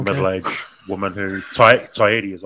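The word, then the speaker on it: Taieri: And he was just trying to say, "Look, it's Tyeti. That's how it's pronounced. It's Maori."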